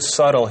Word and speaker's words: subtle: Notice the word subtle here. When Vicky said it, you do not pronounce the B sound. The B sound is silent, so you pronounce this word subtle.